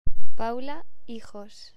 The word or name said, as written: Paula Hijos